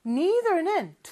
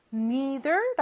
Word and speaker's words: neither: neither는 두 가지 발음이 있어요. neither 라고 해도 되고요. neither 라고 해도 돼요.